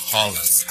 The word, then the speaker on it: Haaland: Entonces los alemanes no lo pueden pronunciar. Igual, entonces por eso le dicen Haaland, pero su nombre es Haaland.